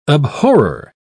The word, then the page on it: abhorrer: British English